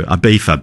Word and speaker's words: Ibiza: But also Ibiza, baby. – Ibiza, Steve. – So say it right.